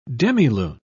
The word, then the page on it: demilune: British English